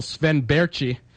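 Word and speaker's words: Sven Bärtschi: Starting in Calgary, where the Flames have sent back to Portland of the WHL, Sven Berchi. – Way to pronounce it right. It's a tough name to pronounce.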